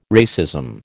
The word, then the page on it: racism: American English